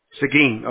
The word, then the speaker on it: Seguin: how do you pronounce it, Seguin? – Seguin, okay, Seguin.